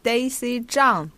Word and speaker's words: Daisy Zhang: Daisy Zhang, but I think you pronounce pen fantastic.